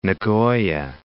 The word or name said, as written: Nagoya